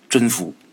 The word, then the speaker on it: 甄宓: 这宓呢是一个宝盖下边加一个必须的必，这字是一个多音字，也念密。哎，甄宓。大家伙不知道甄宓也没关系，但是大家伙应该知道曹植所著的《洛神赋》吧？翩若惊鸿，宛若游龙。哎，这《洛神赋》啊，其实就是写甄宓的。